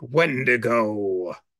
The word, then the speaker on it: Wendigo: And you have to pronounce it like the arcade game, like Wendigo.